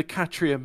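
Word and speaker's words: Khatyrka: So this is an X-ray diffraction pattern from the icosahedrite sample found in the Kachira meteorite. – Excellent pronunciation, like a native. – Yeah, this is from the Kachira meteorite showing fivefold symmetry.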